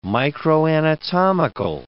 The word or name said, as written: microanatomical